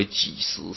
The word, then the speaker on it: 给: 我这是几个故事啊，哈，或为见死父母之亲呐、或为给食，这个给念应该念作给，对不对？或为给食是吧？供给的给，给食啊，